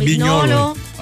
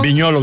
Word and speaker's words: Vignolo: Se escribe Vignolo, pero es Vignolo. – Ajá. Miguel Simon, Viñolo.